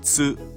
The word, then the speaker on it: つ: This is a little more difficult because it includes two irregular syllables.Pronounced chi, not ti, and tsu, not pronounced tu.